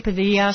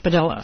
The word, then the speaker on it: Padilla: I want to say Padilla so badly, but he pronounces it Padilla. So in deference to his own pronunciation of his own name, I will call him Padilla.